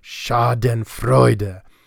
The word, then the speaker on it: Schadenfreude: Four syllables, in its own little trochaic meter. Schadenfreude.